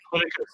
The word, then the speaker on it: Politicus: Politicus, so I hope I'm saying it right. – Politicus is just fine. That's what I say. – Like Leviticus, but much better.